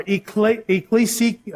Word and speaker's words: ekklesia: The word church in Greek means ekklesia. I'm sorry, ekklesia. I can't say it. Ekklesia. Ekklesia. That's what it says. That's how you pronounce it.